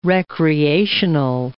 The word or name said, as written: recreational